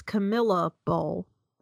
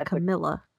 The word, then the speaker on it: Camellia: Okay, well, first of all, it's Camellia Bowl. That's how you say it, sir. It's a Camellia.